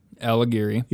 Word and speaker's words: Alighieri: Alighieri.